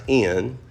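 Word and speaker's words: in (ἐν): The English word is in, the Greek word is pronounced in, epsilon nu, I N, either way you spell it, it's in.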